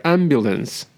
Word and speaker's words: ambulance: 所以是 ambulance，俺不能死。你下次看见救护车，马上想起来，俺不能死。哎，这单词也记住了。